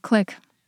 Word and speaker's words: clique: It's a little bit of a low-frequency word, but it's spelled C-L-I-Q-U-E. How do you pronounce that word? – Click. – Yeah, click. – Yeah. So the interesting thing is that in the UK, they pronounce it click. And very commonly in academic contexts, especially this is a word that comes up in graph theory, it's pronounced click as well.